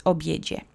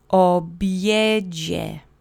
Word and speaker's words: obiedzie: The accent is on the middle syllable. There aren't any special phonemes. Pay attention to the DZI letter combination. That's the J sound. Obiedzie. Obiedzie.